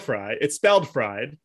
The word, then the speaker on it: Fried: One thing that's a little, you know, on topic for the holiday where you eat fried foods, which is that Max Fried, it's spelled fried. Even though it's pronounced Fried.